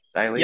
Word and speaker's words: Xia Li: And they paired her up with Xia Li. Is that, I think that's how you pronounce it, Xia Li? – Yep.